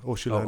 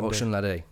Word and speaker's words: Osunlade: And then in terms of lineup, pronounce his name again. Osunlade. – Osunlade. – Oh, yeah. I think I got it there.